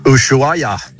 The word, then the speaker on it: Ushuaia: Ushuaia.